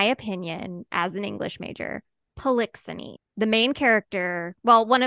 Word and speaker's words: Polixenes: Polixenes. Yeah.